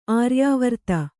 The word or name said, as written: ಆರ್ಯಾವರ್ತ